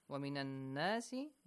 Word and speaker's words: ومن الناس: ومن الناس نون مشدد here ومن الناس make sure it's pronounced with a nasal sound.